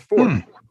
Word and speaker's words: forte: But in French, it's pronounced fort. And in English, it is supposed to be, technically, if you look in the dictionary, the first definition or first pronunciation is the without the E is silent. It's just fort.